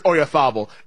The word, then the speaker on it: Oyarzabal: I mean, but like the golfer Jose Maria Oyarzabal or whatever, like nobody was pronouncing the Z.